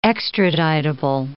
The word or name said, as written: extraditable